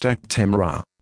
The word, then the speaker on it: Actemra: This is the consumer medicine information for Actemra and Actemra SC prefilled syringe, pronounced Actemra.